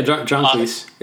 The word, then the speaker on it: John Cleese: And then Luke shared with me John Cleese. Is that, am I saying that right? – Yeah, John Cleese.